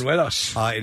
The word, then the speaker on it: buñuelos: Buñuelos.